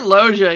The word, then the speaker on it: Loggia: speaking of Sykes, Robert Loggia, is that how you pronounce his name? – It's R, as in Robert Loggia. – Loggia, thank you.